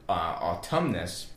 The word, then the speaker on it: autumn: But the word autumn is the older of the two, and that comes from the Latin word autumnus, which is why our contemporary word autumn has that N on the end, that silent N, is because that when it was this Latin word autumnus, it was actually, the N was actually pronounced.